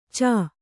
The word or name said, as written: ಛ